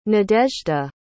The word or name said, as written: nadezhda